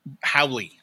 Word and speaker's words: haole: So the term is Howley. – Howley. – Yeah. – Howley. But it's spelled H-A-O-L-E.